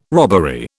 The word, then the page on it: robbery: British English